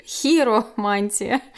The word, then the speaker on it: хиромантия: Хотя она, мало того, что она не популярная, многие люди думают, что она вообще пишется через Е, но на самом деле она пишется через И. Hero Мантия.